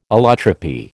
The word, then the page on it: allotropy: American English